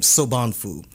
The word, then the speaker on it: Sobonfu: Now, again, that may be controversial to some, but I encourage your listeners to check out this an African spiritual teacher named Sobhanfu. – Mm-hmm. – And it's spelled just like it sounds, S-O-B-O-N-F-U. Sobhanfu talks about how in many African cultures and tribal origins, queer men were considered the gatekeepers.